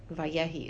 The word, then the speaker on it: Vayehi: Okay, Vayehi is the pronunciation based on the pasuk, I assume he was quoting, okay, Hu Amar Vayehi. That's how the emphasis is on the end there.